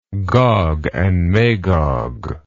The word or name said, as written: Gog and Magog